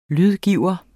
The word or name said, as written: lydgiver